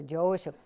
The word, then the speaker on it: Joshu: So much easier, Joshu.